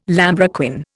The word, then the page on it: lambrequin: Indian